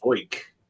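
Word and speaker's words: Zeuch: And how do you pronounce that random new guy's name? Zoik? – TJ Zoik. – Zoik. – Sounds like Zoinks.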